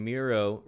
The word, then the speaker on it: Miro: And Miro rhymes with hero.